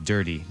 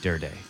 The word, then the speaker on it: Durde: He did say it's pronounced Durde, not Durde, which we all thought.